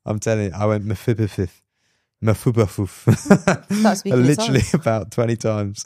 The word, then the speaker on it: Mephibosheth: It's Mephibosheth.